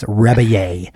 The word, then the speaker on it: Rebillet: Rebillet. – It's spelled like Rebillet. It's spelled like Rebillet, but it's Rebillet.